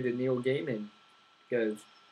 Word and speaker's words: Gaiman: It's definitely, as much as we shouldn't trust Joel on how to pronounce people's names, it's Gaiman.